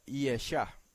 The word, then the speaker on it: Yesha: Tercera palabra, número 03468 en la concordancia de Strong, 03468, y se escribe también con Yod Shin Ayin, pero esta vez se pronuncia Yesha. Debajo de la Yod van dos puntitos, uno al lado del otro, esa es la E, y debajo de la Shin una rayita que es la A.